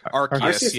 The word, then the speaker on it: Arceus: But isn't this basically Pokemon Arceus? I don't know how to pronounce it. Like Arceus, yeah.